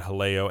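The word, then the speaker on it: Jaleo: This is Almuerzo Rapido Fixed Price Lunch Debuts at Disney Springs Haleo. Which I believe I pronounced that right. We had a lesson in that one time.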